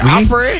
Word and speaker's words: Opry: How you say it? – Opry. Opry. Yeah, Opry.